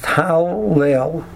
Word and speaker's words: Hallel: So the word Hallel really should be pronounced Hallel. There should be three Lamets. Hal, Hey Lamet, Lel, Lamet, Lamet. But instead of writing it with three Lamets, we only write it with two Lamets, and we put a Dagesh in the Lamet.